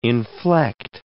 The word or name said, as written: inflect